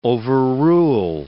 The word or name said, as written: overrule